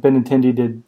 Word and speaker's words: Benintendi: Andrew Benintendi.